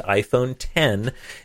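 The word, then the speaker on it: iPhone X: It's the letter X, the Roman numeral X, but pronounced iPhone 10, and it is, as expected, the edge-to-edge display version.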